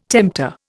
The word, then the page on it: tempter: Indian